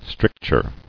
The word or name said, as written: Stricture